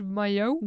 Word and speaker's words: maillot: in French it's pronounced maillot.